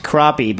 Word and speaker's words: crappie: It's pronounced crappie, but written crappy.